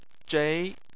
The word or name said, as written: zhei2